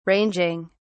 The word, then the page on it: ranging: British English